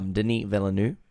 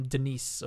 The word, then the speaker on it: Denis Villeneuve: So obviously we're talking about the film of the week and our latest director's corner, Denis Villeneuve, as we found. – You had to check that pronunciation. I'm glad we did, because I would have called him Denis or Deniz.